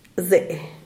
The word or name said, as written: zee